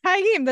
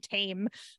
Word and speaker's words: Taim: Taim, that sounds so much better than Mazdurm Tame.